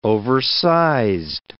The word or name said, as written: oversized